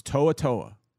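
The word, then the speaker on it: To'oTo'o: I'm like, it's Toa-Toa.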